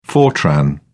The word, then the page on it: fortran: British English